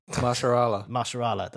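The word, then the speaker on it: Mahershala: again like after the rewrites and stuff, but basically the only one who was actually cast was Maharaja Ali. I can never say his name. – Maharshala, that's it.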